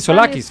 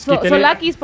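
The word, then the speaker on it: Tzolakis: Es Tosolakis, ¿no? Sí, Solakis. – Solakis, porque la T es muda.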